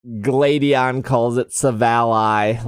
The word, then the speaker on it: Silvally: Silvally's drives. Also, I think we've learned that it's pronounced Silvally, right? That's how the anime says it.